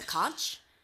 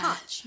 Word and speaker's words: conch: Because that's something that's always rubbed me the wrong way is when people say conch. – I know. – Instead of conch.